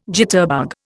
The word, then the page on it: jitterbug: Indian